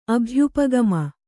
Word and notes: ಅಭ್ಯುಪಗಮ: kannada

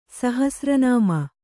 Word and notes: ಸಹಸ್ರನಾಮ: Kannada

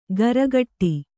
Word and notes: ಗರಗಟ್ಟಿ: garagaṭṭi

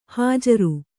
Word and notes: ಹಾಜರು: hājaru

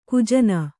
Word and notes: ಗಜನಿ: Kannada

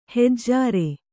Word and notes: ಹೆಜ್ಜಾರೆ: hejjāre

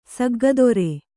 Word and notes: ಸಗ್ಗದುರು: saggaduru

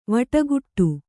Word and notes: ವಟಗುಟ್ಟು: kannada

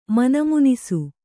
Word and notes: ಮನಮುನಿಸು: mana munisu

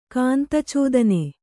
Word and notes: ಕಾಂತಚೋದನೆ: kāntacōdane

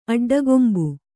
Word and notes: ಅಡ್ಡಗಂಬಿ: aḍḍagambi

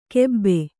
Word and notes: ಕೆಬ್ಬೆ: kannada

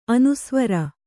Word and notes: ಅನುಸ್ವಾರ: anusvāra

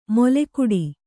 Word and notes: ಮೊಲೆಗುಡಿ: moleguḍi

